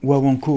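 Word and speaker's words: guaguancó: Pas écorcher le nom du genre, et tu m'as dit que c'était de la rumba guaguanco. – Guaguanco, ouais, guaguanco.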